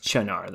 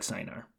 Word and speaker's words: Cynar: It is pronounced Chinar, though. But yeah, it looks like Cynar.